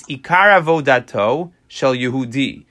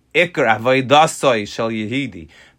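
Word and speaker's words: Ikar avodasoi shel Yehudi (עיקר עבודתו של יהודי): His line is, Ikar avodato shel Yehudi. I'm sure he didn't pronounce it that way, but it's Ikar avodasoi shel Yehudi.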